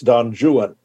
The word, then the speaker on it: Don Juan: So to be clear, the English uh, uh, language equivalent uh, of this is probably Lord Byron's Don Juan, uh, which is how it's pronounced because it rhymes. He has it rhyme, rhyming with new one and true one. Uh, so it's Don Juan.